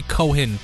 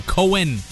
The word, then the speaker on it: Cohen: Why did I say Cohen? Cohen. Said it wrong.